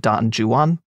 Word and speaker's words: Don Juan: This is literally the guy who wrote Don Juan, or as he pronounced it, Don Juan. I think just because he was English, not as like a weird, yeah.